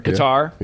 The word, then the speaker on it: Qatar: That Qatar, if that's how you pronounce the country. – Qatar.